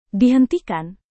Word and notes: dihentikan: Indonesian

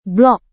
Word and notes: blog: indonesian

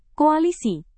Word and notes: koalisi: Indonesian

